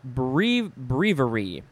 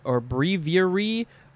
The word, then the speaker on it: breviary: Next is a word that can be pronounced 1, 2, 3, 4, like 5 different ways. Breviary, or breviary, or breviary, breviary. It's different ways. It's spelled B-R-E-V-I-A-R-Y, breviary. This, it's not bravery, that's a different word.